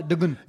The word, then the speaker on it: Devgn: She told Ajay Devgan to take that letter out of his name, you know, because it's spelled D-E-V-G-N. And it's Devgan. – Oh, Devgan.